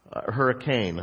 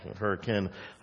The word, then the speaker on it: hurricane: Hurricane. I'm so used to pronounce hurricane. I got corrected so many times when I moved here. Hurricane. It's hurricane, not hurricane.